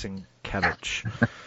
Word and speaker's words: Sienkiewicz: I always say it wrong the first time. It's Sienkiewicz.